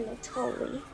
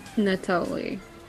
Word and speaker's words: Natoli: I'm going to say his name like that. Mr. Natoli. That's how she said it the whole movie. It sounds like Nutella, but it sounds like sexy Nutella.